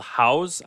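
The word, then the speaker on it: Haus: The first is, it's called House, I think you pronounce it. It's H-A-U-S, drink.house.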